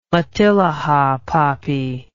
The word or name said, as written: matilija poppy